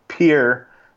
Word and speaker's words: Pierre: and said it's actually pronounced Pierre, and so they put that in the soundtrack itself. So there you go. Not that you were wondering, but it is pronounced Pierre, South Dakota, and all the South Dakotans rejoiced.